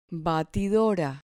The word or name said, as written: batidora